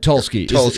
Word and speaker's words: Tulsky: Yeah, I'm sure anybody that in a position that Tursky is in, is that how you pronounce it? – Eric Tulsky.